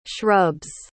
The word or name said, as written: shrubs